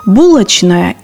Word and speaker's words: булочная: В некоторых словах возможны варианты – «булочная» и «булочная», «прачечная» и «прачечная».